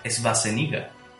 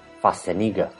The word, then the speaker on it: Schwarzenegger: Pero que realmente, como se pronuncia, como se pronunciaría, según tengo entendido, es Schwarzenegger. – Schwarzenegger. Sí, bueno, en alemán la pronunciación más o menos sería así. Arnold Schwarzenegger.